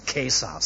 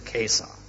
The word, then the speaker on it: Caesar: Jeder, der heute Latein lernt, weiß wieder, dass man Caesar sagen muss. Wir haben damals drüber gelacht. Aber heute heißt das wieder der Caesar. Das C muss mit K ausgesprochen werden.